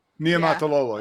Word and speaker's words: Niumatalolo: Niumatalolo, yeah.